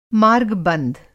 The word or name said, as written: मार्ग बंध